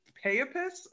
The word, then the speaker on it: Peipus: And for those of us that don't know where the heck Estonia is, or what Lake Peipus, I think was the correct pronunciation.